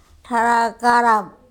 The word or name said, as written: THarakarap